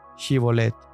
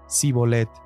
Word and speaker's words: Shibolet: Si él respondía, No, entonces le decían, Di pues la palabra Shibolet. Pero él decía, Sibolet, porque no podía pronunciarla correctamente.